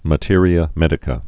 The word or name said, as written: materia medica